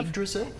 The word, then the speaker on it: Yggdrasil: So the Tribes of, how do I pronounce it? – The tribes of Yggdrasil. – Yggdrasil, and what does Yggdrasil mean? – Yggdrasil's the world tree.